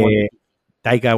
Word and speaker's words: Waititi: ya directamente es, Taika Waitiki o como coño se llame, nunca lo digo bien. – Taika, eh, Waititi.